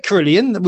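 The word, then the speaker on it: Kirlian: No, you say Carillion, which is absolutely what you said. Real good, spot on.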